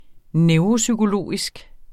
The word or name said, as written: neuropsykologisk